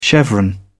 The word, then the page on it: chevron: British English